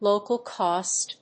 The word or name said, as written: local cost